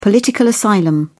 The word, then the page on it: political asylum: British English